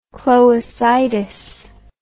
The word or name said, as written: cloacitis